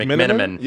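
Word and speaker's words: McMenamin: Shannon Sharp apparently granted an interview to, I think it was Dave, if I'm is pronouncing it McMiniman. – Yep, okay, Lakers I was trying to pronounce his name.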